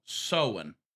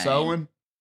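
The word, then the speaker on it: Samhain: Well, it's actually spelled S-A-M, Sam, H-A-I-N, like But it's Sam called Hain, sowing? – But it's pronounced as sowing.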